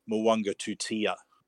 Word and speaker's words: Muwanga Tutia: Yeah, we recently had our own advice that we received on one of the junior rep families, that the correct pronunciation, we were saying Muaga Tutia, but it is Muwanga Tutia. – That's right. So you got that sort of silent N into a... – Yes. – And obviously the back end of it being pronounced differently too. So yeah, just one of those things. Into the long U. – Yeah. – Long U sound.